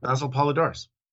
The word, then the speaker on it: Basil Poledouris: but Basil Poledouris? – Yeah, Basil. Yeah, Basil Poledouris.